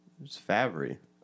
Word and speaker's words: Favre: It's Favre.